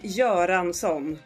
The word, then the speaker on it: Göransson: Ludwig Göransson.